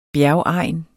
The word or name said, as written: bjergegn